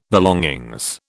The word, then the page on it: belongings: British English